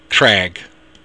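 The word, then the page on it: creag: Irish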